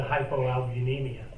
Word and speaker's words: hypoalbuminemia: And her actions, actually, she immediately reviewed the blood work and noted the hypoalbuminemia.